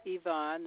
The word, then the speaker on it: Yvonne: My first name is Yvonne. It's spelled Y, V as in Victor, O-N-N-E. Last name Mickens, M-I-C-K-E-N-S.